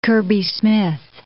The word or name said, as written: Kirby-Smith